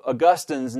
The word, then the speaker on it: Augustine: Second, however, while consideration of the Latin original of Augustine's name does not determine a single grammatically obligatory English pronunciation, it does suggest that Augustine is the more fitting or appropriate pronunciation. This is because the latter most closely preserves the distinctive placement of the accent in the original.